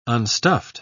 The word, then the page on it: unstuffed: British English